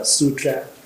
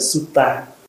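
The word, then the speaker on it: sūtra: Thì kinh á gọi là tu đa la hay là sutra, tiếng Phạn là sutra, đọc theo tiếng Phạn, âm Bắc Phạn là sutra, tiếng Pali là sutta.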